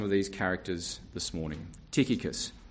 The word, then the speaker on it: Tychicus: And so firstly, our first man, Tychicus. Tychicus, the CH is pronounced with a K in the Greek. Tychicus is the other pronunciation.